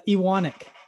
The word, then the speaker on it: Iwanek: Iwanek. – Iwanek. – Iwanek. I know. It's like it's spelt like Ewan.